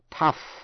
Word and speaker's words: tough: Slough off former associates, bỏ những cộng sự viên cũ, nghĩa là một thái độ vắt chanh bỏ vỏ, cũng đọc là off, trong chữ enough là đủ, E-N-O-U-G-H. That's enough, đủ rồi. Tough, T-O-U-G-H, tough, là cứng rắn hay là dai nếu là thịt.